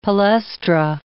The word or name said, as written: palaestra